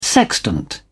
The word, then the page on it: sextant: British English